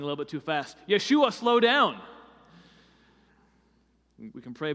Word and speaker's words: Yeshua: In the first century of Palestine, you would probably hear it pronounced Yeshua. Was probably more how they said it.